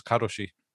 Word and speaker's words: karoshi: By the way, the term for a work to death is karoshi. – Nice. Good. Right on. – Karoshi. That's how you say it.